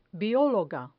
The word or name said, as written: biologa